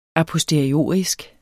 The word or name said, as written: aposteriorisk